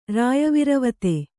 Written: ರಾಯವಿರವತೆ

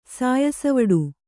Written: ಸಾಯಸವಡು

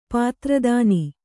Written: ಪಾತ್ರದಾನಿ

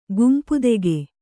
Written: ಗುಂಪುದೆಗೆ

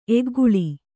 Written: ಏಕಕಾಲ

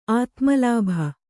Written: ಆತ್ಮಲಾಭ

